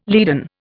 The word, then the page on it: leaden: Indian